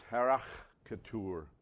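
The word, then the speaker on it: harachtur (χαρακτήρ): The way it's pronounced is harachtur, okay? Harachtur.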